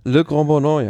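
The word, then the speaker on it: Le Grand-Bornand: Geht es sozusagen in den Nordosten, fast schon an in Richtung der Schweiz mit der Etappe von Albertville in Annecy nach La Grand Bornand oder irgendwie so ähnlich ausgesprochen. – Le Grand Bornand, ja.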